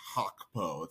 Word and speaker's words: Gakpo: The funny thing is, I think the way it's pronounced in Dutch, I think even we are pronouncing it incorrectly. The G is like a guttural sound. It's when I've heard like, I think like Van Gaal say his name before, it's like, it's Hakpo. It's like all the way in the back of your throat.